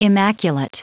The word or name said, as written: immaculate